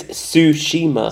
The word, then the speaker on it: Tsushima: Ghost of what? – Shizima? – Nope. – Is that how you say it? – I think it's Tsushima.